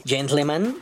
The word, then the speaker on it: gentleman: Ah, mi queridísimo Marcelo Albán, que me enseña cómo decir gentleman. Primero pronuncias gen, luego tl y luego man.